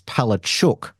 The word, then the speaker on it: Palaszczuk: The chook is cooked because, according to many Polish Australians, the correct pronunciation of her name is Palaszczuk, not hence the Chooks.